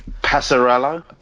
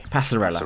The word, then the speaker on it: Passarella: He was the Argentinian manager, and he was their their, their, their, their 1978 World Cup winning skipper, Daniel Presella, I think. – Passarella. – Passarella. Okay, Passarella.